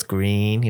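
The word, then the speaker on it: GReeeeN: It's a song by this band called Green with like four E's. So it's Green. You gotta say the long E.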